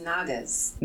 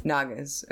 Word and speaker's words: Naga: That's Nagas. – Nagas. Am I mispronouncing it? – Yes. – What? Naha? – It's Naga. – Naga. – N-A-G. – Oh, like, they spelt right. It with a J, so then I pronounced it like an H.